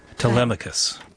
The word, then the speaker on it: Telemachus: Telemachus.